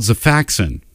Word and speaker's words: Xifaxan: Starts with a Z? – It's, no. But the pronunciation does. – What is it? – This one's actually called Xifaxan.